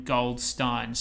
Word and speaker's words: Goldstein: She explicitly pronounced her name Goldstein, so much so that I make a point of regularly correcting people in Parliament.